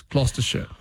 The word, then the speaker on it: Gloucestershire: Like, I remember an American saying, what's this place, Gloucestershire? – Yeah. – And it's Gloucestershire.